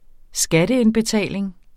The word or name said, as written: skatteindbetaling